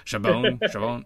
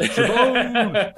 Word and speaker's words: Chabon: Chabon. Yeah. Sure. He like yells it out a couple times in the episode. Chabon.